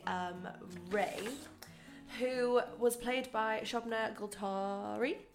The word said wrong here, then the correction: Gulati: Gulati? – Gulati. Yeah. Sorry, I we butchered that.